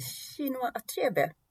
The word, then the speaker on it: Chinua Achebe: Vi har läst Allt går sönder av Chinua Achebe. Ja. Om man nu uttalar det så, det vet jag inte men eh, ja. Jo men det, det är så jag skulle uttala det också. Ja.